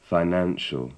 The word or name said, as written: financial